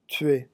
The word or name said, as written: tuer